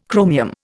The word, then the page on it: chromium: Indian